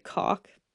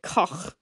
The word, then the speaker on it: Koch: And then there was a so this guy's last name is German. So I think we'd pronounce it cock, but I'm gonna pronounce it koch because I am a woman of class.